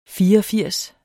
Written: fireogfirs

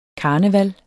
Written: KARNEVALET

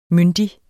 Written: myndig

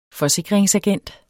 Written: forsikringsagent

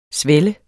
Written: svelle

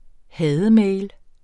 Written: hademail

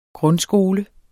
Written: grundskole

